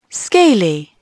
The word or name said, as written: scaly